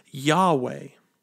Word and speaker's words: Yahweh: We pronounce the word today as Yahweh.